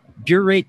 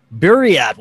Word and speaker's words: Buryat: She has also been welcomed into indigenous cultures, and in 2012, she was initiated as a Mongolian Buryat shaman. Did I pronounce that right? – A Buryat? – Buryat. Wow, I was way off.